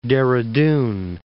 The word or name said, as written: Dehra Dun